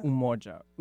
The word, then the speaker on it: Umoja: Emoja. – Umoja. U. – U. – U. So it's U, but it's pronounced as U.